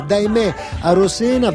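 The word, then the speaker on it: Daymé Arocena: Daimé Arosena, che il nostro Francesco mi ha rimproverato perché l'ho annunciata con la pronuncia errata. Quindi si chiama Daimé Arosena,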